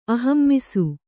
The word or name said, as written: ಆಹಮಿಸು